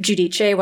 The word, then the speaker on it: Giudice: So it was, she said her daughters and her and Joe had never really been bothered by the American pronunciation, but they did correct people and try to pronounce it Giudice while Joe's father was still alive.